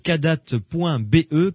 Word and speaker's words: Kadath: Allez sur leur site kadat.be. Kadat, ça s'écrit kadath.be.